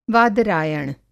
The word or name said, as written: वादरायण